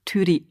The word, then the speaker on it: Turid: und mich Turit genannt haben obwohl dieser Name eigentlich Tyri ausgesprochen wird.